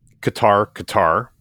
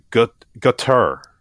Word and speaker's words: Qatar: Business people pronounce Qatar, but the State Department pronounces it something closer to gutter, almost like gutter. – Gutter? – Yeah, so gutter. Yeah. But I think gutter is actually closer to the correct pronunciation.